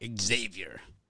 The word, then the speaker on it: Xavier: We're going to go Seton Hall plus the points, Baylor minus the points, and Xavier minus the points. – Xavier. It's Xavier with a Z. – Xavier. – With a Z. Like it's spelled.